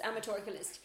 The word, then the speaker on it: amatorculist: I was saying a matriculist, but it's amatorculist.